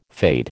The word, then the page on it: fade: American English